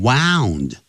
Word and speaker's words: wound: 好，我们现在来看 wind 过去时跟过去分词分别念成都是念成 wound，到头来。